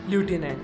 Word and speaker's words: lieutenant: Sophisticated, S-O-P-H-I-S-T-I-C-A-T-E-D, sophisticated, which is correctly pronounced as sophisticated, sophisticated. Lieutenant, L-I-E-U-T-E-N-A-N-T, lieutenant, which is correctly pronounced as lieutenant, lieutenant, lieutenant, lieutenant. Colonel, C-O-L-O-N-E-L, which is correctly pronounced as colonel.